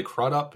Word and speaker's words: Crudup: You know, I think it's Billy Crudup is how you say his last name that did...